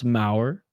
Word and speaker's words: Mauer: Second off, it literally rhymes with a brand that you absolutely know because you cover hockey. No, I just I, I don't follow baseball. I just wanted to confirm that it's pronounced Mauer.